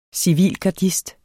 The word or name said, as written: CIVILGARDER